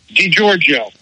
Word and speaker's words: DiGiorgio: DiGiorgio. – DiGiorgio. – DiGiorgio. Okay. – You was calling him DiGiorno like the pizza.